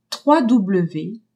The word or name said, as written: trois w